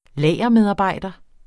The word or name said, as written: lagermedarbejder